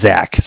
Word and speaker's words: Zach: Zach, spelled Z-A-C-H.